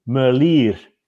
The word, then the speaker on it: Merlier: Okay, I'll give you a pronunciation lesson. It's for everybody there. It's Tim Merlier. It's like the ear on the side of your head, Merlier. Because he's Flemish, he's not French, he's not Merlier, he's Tim.